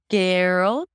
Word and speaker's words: girl: So it's not girl. It is definitely not girl. There is no I there. And we already eliminated the representation of this word from your brain, so you don't know how it's spelled, right? So again, here it's a G to the R, grr. And then we have the dark L sound.